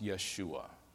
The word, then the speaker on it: Yeshua: The Hebrew word for salvation is pronounced Yeshua.